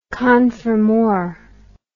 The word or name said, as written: confirmor